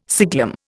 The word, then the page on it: siglum: Indian